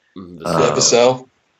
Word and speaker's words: Vassell: You know, the freshman, I forget how to say his name, but is it Vassell or Vasselli? – Vassell. – You know, yeah, Vassell. Yeah, he hit that shot.